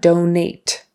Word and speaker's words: donate: We want donate, donate. And the emphasis is on the first syllable, donate.